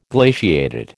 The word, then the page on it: glaciated: American English